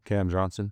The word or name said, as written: Cameron Johnson